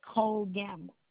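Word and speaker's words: Cole-Gamble: My first name is Melody, M-E-L-O-D-Y, and my last name is Cole Gamble. – And could you spell that for me? – M-E-L-O-D-Y, first name, last name C-O-L-E dash G-A-M-B-L-E.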